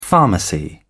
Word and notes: pharmacy: British English

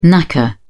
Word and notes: knacker: British English

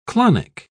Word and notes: clonic: British English

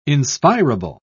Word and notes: inspirable: British English

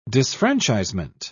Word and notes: disfranchisement: British English

